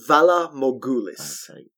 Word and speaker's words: Valar Morghulis: I think it's Valar Morghulis. Okay. – Valar Morghulis. – Valar Morghulis. – That'll do it, yeah.